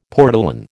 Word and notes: portolan: American English

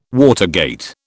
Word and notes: watergate: British English

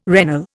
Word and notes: regnal: Indian

